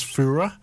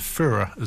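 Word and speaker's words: Feura: That was Fura, F-E-U-R-A. I'm going with Fura as the pronunciation of that.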